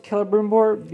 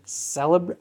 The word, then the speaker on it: Celebrimbor: So Celebrimbor, I guess is how I pronounce it Celebrimbor, because I've just heard that way more than Celebrimbor. I don't know. For me, Celebrimbor sounds very weird.